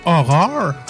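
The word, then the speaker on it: Ho99o9: HO9909, qui se prononce dans le fond horreur. – Ah, OK. – Parce que 9, c'est le R qui est la neuvième lettre euh, de, l'alphabet.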